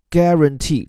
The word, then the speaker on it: guarantee: guarantee，注意这个词当中的 u 字母不发音，而它主导的句式呢，既可以是 guarantee that，也就是跟上一整个长句，比如说我保证你一定会喜欢这部电影，I guarantee you'll love this film，也可以是 guarantee somebody something，再或者是 guarantee to do something。